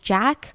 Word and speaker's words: Jacques: Now I don't know if it's pronounced Jack because that's what I read that you pronounce this name as Jack, which would be very infuriating because that is a very fancy way of spelling Jack, but it's J A C Q U E S okay?